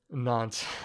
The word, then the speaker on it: Nantes: Nantes. – Oh man. Oh my goodness, these pronunciations. – Oh, the French, the French is gonna get you. – Oh yeah. So Nantes. – Yes. – Was it Nantes?